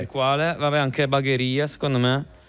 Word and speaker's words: Bagheria: Poi dopo Brescia vai a Padova, Bologna, Genova, Francavilla al Mare, c'è Roma, Salerno, Cosenza, Catania, Bari, Bagheria o Bagheria, non ho idea Bagheria della pronuncia. – È in Sicilia, fuori Palermo. Ok, sì, Bagheria.